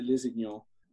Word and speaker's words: Lusignan: The actor Martin Sokas has the most hammy, almost pantomime performance in the film as Guy de Lusignan. And I pronounced that right.